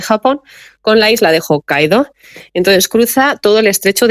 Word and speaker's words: Hokkaido: Igual que se desconoce otro perfil de islas que realmente están siendo emergentes y de hecho a la de Hokkaido,